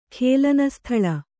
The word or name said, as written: ಖೇಲನಸ್ಥಳ